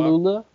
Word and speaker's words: Gelula: How do you say G- Dylan Gelula. – Gelula.